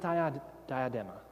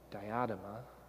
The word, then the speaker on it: diadema: Now, the word here, and I've already alluded to this, is not, Greek word is not diadema. Okay, I just mispronounced it. Whatever, it's fine. It's not diadema. Diadema, which would be royal crown.